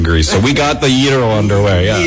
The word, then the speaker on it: gyro: You probably hear Canadians call it a gyro, G-Y-R-O. How do you say it? – Gyro. Well, gyro. Some people can pronounce it, but which is okay. But if you can do the E, that's okay. That's cool. Gyro.